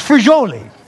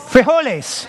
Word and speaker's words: frijoles: There's just frijoli. Frijoli, right? What's frijoli? Wait, quiet. Frijoles. Okay. There's no frijoles. No, there's loads of frijoles. There's mucho frijoles. There's machismo frijoles.